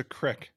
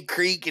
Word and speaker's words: creek: What's a crick? – That's how he says it. Me, that's one thing we say different. I say creek and he says crick. It's spelled C-R-E-E-K.